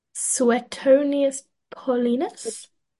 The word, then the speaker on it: Suetonius Paulinus: Suetonius Paulinus.